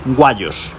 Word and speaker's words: Wayos: Estoy hablando de Wayos, yo lo voy a decir así, es WAYOS, Wayos. Yo lo pronuncio así como suena.